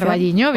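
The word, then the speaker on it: O Carballiño: Aquí tienes ventaja, vamos a hablar de la fiesta del pulpo, que se celebra en Ocarvalliño, no lo sé pronunciar. – Ocarvalliño, bien, exacto.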